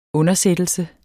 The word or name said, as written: undersættelse